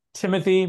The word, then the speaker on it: Thimothy: It's Timothy. Pretend it didn't have an H in it.